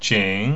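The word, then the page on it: qǐng: third tone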